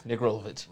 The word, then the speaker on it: Nick Rolovich: And, I mean, Nick Rolovich, that's how you pronounce his name, right? – Yeah, Nick Rolovich.